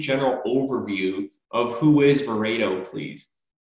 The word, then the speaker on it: Verato: Sure. It's Verato. – Oh, I apologize, Verato. – No worries at all. No worries at all. Everybody, it's pronounced every, you know, for every human, there's a different pronunciation of it, but it is Verato.